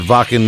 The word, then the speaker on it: Wacken: at Wacken Metal Fest.